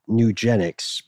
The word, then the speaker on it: Nugenix: Sinclair noted that the source of this operation, a commercial company called Nugenix, pronounced like eugenics,